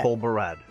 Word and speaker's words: Tol Barad: Barad, how do you spell that? Say that. – Tol Barad. – Tol Barad, thank you.